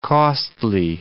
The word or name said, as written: costly